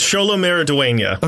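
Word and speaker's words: Xolo Maridueña: That one's right. I wrote it phonetically. I listened to interviews with him, but it's Sholo Maraduena.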